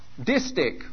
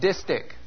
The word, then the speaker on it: distich: And the two-part line itself as a distich, which is not to be mispronounced as dipstick, okay? Stick and distich.